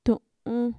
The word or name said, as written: tu'un